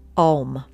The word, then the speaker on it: Om: And that is to be pronounced like the O vowel sound, not an ow. So Om, like O, Om.